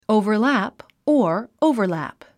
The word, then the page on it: overlap: òuvə r lǽp; オゥヴァラプ